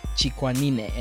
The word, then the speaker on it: Chikwanine: Yeah, so my name is Michel Chikwanine, and it's pronounced chick, like a little baby chicken. – Chick. – Wah, like a baby crying. – Wah. – Ni, the knee that we all have, and then ne, the sound the horse makes.